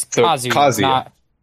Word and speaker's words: Kazuya: Sean says the first syllable gets the emphasis, so it's Kazuya.